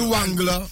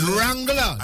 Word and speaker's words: Wrangler: It's not Wangler. It's Wrangler. The W is silent.